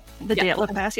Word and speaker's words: Dyatlov: It's so creepy because I always had a fascination around the Dyatlov Pass. I don't know if I'm pronouncing Dyatlov Pass.